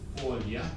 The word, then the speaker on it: folha: Okay, the Portuguese forms would be for the verb to do or to make is fazer, F-A-C-E-R, fazer. The word for son is spelled F-I-L-H-O, pronounced filho. The word for leaf is folha, F-O-L-H-A. This L-H is a Portuguese orthography for a y sound.